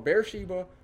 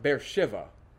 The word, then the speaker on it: Beersheba: Just as the Bible said, it will be called Beersheba unto this day. It still is. There is a large modern city built just outside of this picture. They call it Beersheba. They pronounce it with a V.